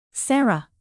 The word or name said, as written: sarah